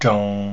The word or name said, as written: zhong